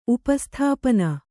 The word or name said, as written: ಉಪಸ್ಥಾಪನ